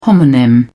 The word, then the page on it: homonym: British English